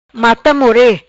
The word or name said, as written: Mata more